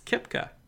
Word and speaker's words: Koepka: Brooks Koepka. – Okay. – I think I'm pronouncing that right. – Actually, you are. Actually, no. Yeah, you are.